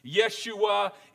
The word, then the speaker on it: Yeshua: Yeshua is the Hebraic, not transliteration, it's the Hebraic pronunciation of the name Joshua.